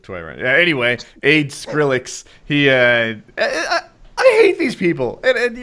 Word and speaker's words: Skrillex: Did I say it wrong? – That's Skill Rex. – How do you say it? – Skrillex.